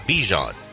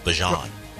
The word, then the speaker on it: Bijan: And yeah, he sat down and told everyone, it's not Bijan, Bijan.